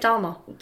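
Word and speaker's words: Dahmer: Jeff Dharma. – I didn't know that's how you pronounced his surname, which Dharma.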